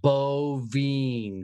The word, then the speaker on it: Bovien: I'm going to be like Bovine, because that's how you pronounce the fucking thing.